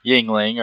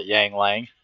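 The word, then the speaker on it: Yuengling: I'm drinking Yingling, or as my coworkers call it, Yang Lang.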